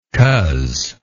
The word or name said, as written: coz